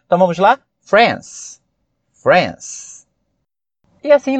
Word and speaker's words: France: Agora, referente à palavra, ou melhor, ao país France, é interessante nós destacarmos que a palavra France, ela é monossílaba. Ela só tem uma sílaba. Apesar de você olhar e, como brasileiro, falante do português, pensar France. Não. É uma pronúncia só. France. Talvez na escrita dê essa ideia pra gente de dissílaba.